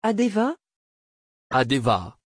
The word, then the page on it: adeva: French